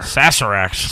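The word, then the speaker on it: Sazerac: Fucking Zazarax. – How did you spell it? – S-A-Z-E-R. – Oh, Sazarax. – Sazarax.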